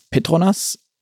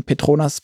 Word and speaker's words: Petronas: Ist es in deinen Augen überhaupt möglich, aus Petronas, ähm, übrigens an der Stelle sei noch gesagt für die Hörer, wir haben vorher gefragt, ist egal, wie man es ausspricht, man kann es also nicht falsch aussprechen, Petronas, Petronas.